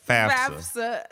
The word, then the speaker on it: FAFSA: FAFSA.